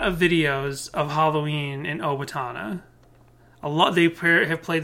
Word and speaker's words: Owatonna: Automata? Autotomia? – Owatonna. – Owatonna. Jessica totally didn't just play a couple seconds of the song and then edit that out so that we knew how to pronounce Owatonna.